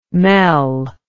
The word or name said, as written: mel